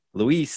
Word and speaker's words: Luis: I'm going to try to spell like a phonetically, a phonetic way of actually saying my name. So that way it's easier for them. And then I clicked, I was like, Lou easy Luis.